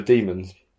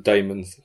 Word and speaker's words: daemons: So I'm writing a book on the demons, which I found out is pronounced the demons and not the daemons, as I've been saying.